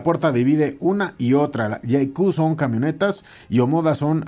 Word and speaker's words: JAECOO: Y bueno, eh, también el día de hoy le, le, tengo información de una nueva marca que se llama JQ. Se escribe J-A-E-C-O-O.